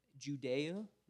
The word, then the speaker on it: Judea: Judea, there's three syllables there, and in Samaria and unto the uttermost part of the earth.